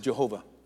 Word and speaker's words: Jehovah: We pronounce it Jehovah. In the Hebrew language, there are no J's. They're pronounced Y's.